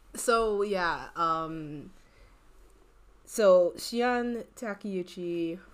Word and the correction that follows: Shion: much how you don't pronounce it. – Yes, it is. I just Googled it. I just Googled the pronunciation. – Okay. – Shion Takeuchi, she worked on Gravity Falls, and she worked on Regular Show.